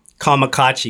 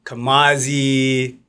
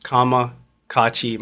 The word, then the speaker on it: KAMAKACCI: So it's KAMAKACCI, because a lot of times people say Kamazi, KAMAKACCI, this, that, and the third. It's pronounced KAMAKACCI.